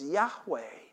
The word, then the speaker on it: Yahweh: And we've gotten that word, which technically, scholars tell us that nobody really knows how to pronounce, because Jewish people would not pronounce the name of God. But by the name it's written in Hebrew, we assume it's Yahweh.